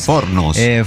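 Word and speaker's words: Fornos de Algodres: Formo de Algordes. – Sí que sí. – Usted entienda mi pronunciación, sí, de todo el portugués. Bueno, Formo de Algordes. – Fornos. – Formos de Algordes.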